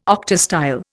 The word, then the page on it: octastyle: Indian